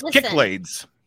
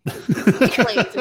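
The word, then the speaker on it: Cyclades: It's Kicklades. – Kicklades, exactly.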